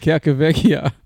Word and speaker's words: Calcavecchia: Mark Calcaveschi. Calcavescia. – Oh, you idiot. Calcavecchia.